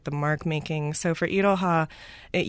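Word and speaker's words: Iroha (いろは): Is it Iroha? Is that the way to pronounce it? – It's Iroha.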